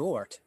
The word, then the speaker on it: Ort: Caleb Wart. Is that how you pronounce the last name? – Wart. It's O-R-T. It's not very hard to pronounce. – Yeah, I said Ort.